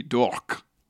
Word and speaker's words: Dourque: Dork like is spelt like a French name. D-O-U-R-Q-U-E. Dork. – Wow, like talk.